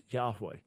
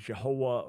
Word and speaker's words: Yahweh: And the consensus among scholars is that the pronunciation was Yahweh. Now, there are some who've tried to argue it was Jehovah or Jehovah. That is a tiny, tiny minority view among scholars.